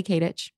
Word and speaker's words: Katich: It's Kylie Katich. Just my name. – K-A-T-I-C-H.